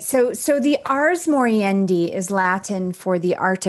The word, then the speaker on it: ars moriendi: And going on to what you talk about throughout the book, I hope I'm saying it right, the ars moriendi. – Yeah, the ars moriendi. – The ars moriendi.